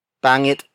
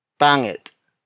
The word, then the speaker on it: pangit: just like the word pangit. Tagalog neutral adjectives can be placed either before the noun or after the noun. For instance, pangit na basura, pangit na basura, pangit na basura.